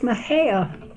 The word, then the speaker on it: Mexia: And my schooling was in Mejia, Texas. And M-E-X-I-A is pronounced Mejia.